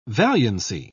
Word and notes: valiancy: British English

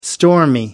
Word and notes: stormy: American English

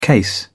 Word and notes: case: British English

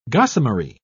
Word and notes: gossamery: British English

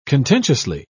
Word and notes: contentiously: British English

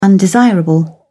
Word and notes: undesirable: British English

